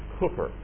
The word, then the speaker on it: Cowper: Some of you have seen his name listed on some of the hymns in our hymnal, spelled C-O-W-P-E-R, and it is not pronounced Cowper. It's Cooper.